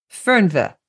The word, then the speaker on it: Fernweh: How do you pronounce that, by the way? – Yeah, I mean, almost Fernweh. – Fernweh, yeah.